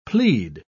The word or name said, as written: plead